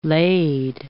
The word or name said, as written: lade